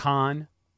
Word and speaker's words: Kahan: They just call him Kahan. – No, that's how you say it.